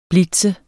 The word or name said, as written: blitz